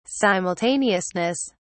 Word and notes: simultaneousness: British English

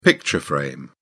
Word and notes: picture frame: British English

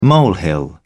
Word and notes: molehill: British English

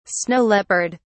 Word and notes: snow leopard: British English

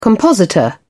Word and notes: compositor: British English

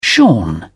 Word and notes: shorn: British English

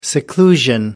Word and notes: seclusion: American English

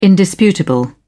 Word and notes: indisputable: British English

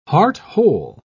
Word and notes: heart-whole: British English

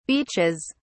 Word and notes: beaches: British English

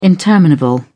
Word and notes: interminable: British English

